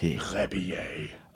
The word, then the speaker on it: Rebillet: The first name is M-A-R-C. – Mark. – The last name is R-E-B-I-L-L-E-T. – Rebier. – Oh, that is so good and so right.